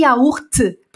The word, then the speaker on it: yaourt: Eu pronuncio todas as letras dessa palavra, porque é uma palavra que vem de outra origem e a língua francesa pegou. Então, quando a língua francesa ela pega uma palavra de outra origem, de outra raiz, de outra língua, o que que acontece? Os franceses pronunciam cada letra, porque tem uma regra geral em francês que não pronuncia o T. E por que que yaourt pronuncia o T? Porque não é uma palavra francesa, tá bom?